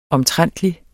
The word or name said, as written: omtrentligt